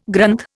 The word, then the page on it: Granth: Indian